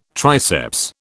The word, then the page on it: triceps: British English